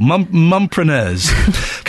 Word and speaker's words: Mumpreneurs: How do I say... Mumpreneurs. I'm adding an extra syllable. Mumpreneurs.